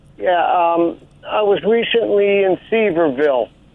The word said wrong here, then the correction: Sevierville: You mean Sevierville? – Dolly.